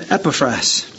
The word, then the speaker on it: Epaphras: It's about a slave, at least a slave to the church, called Epaphras. Or as the Greek pronunciation, Epaphras.